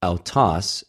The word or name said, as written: αὐτος